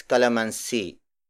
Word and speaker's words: kalamansi: It means six calamansi.